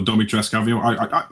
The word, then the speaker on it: Dimitrescu: Lady Dimitrescu, or Domitrescu, I'm sorry, Dimitrescu is how I was pronouncing it before. – The thing is, in the game, everyone calls her Dimitrescu, but Lady D, Alcina, calls herself Domitrescu.